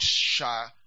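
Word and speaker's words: shachata (שחת): It's a word, shachata.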